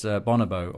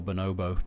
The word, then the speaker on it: Bonobo: One very far away, but it's been announced and worth getting tickets for on October the 22nd, is Bonobo, or Bonobo, depending on your pronunciation.